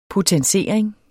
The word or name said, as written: potensering